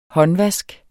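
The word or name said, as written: håndvask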